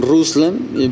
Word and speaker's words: Ruslan: Pues de hecho es como de toda esa zona, porque en Rusia lo pronuncian Ruslan, pero en Checoslovaquia y en países similares le dan variantes de Roslan.